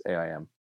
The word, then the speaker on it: AIM: I heard it's actually pronounced AIM.